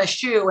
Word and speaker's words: Schuh: And my name is Shawna Schuh, and it's spelled S-H-A-W-N-A and S-C-H-U-H.